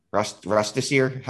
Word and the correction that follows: rotisserie: How are you supposed to say that? – It's rotisserie, but I don't know.